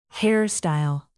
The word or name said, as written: hair-style